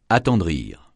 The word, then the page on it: attendrir: francais